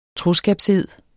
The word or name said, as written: troskabsed